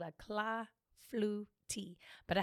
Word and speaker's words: clafoutis: I was calling it clafoutis. Because it has an S on the end, you know, yeah. Has a French S.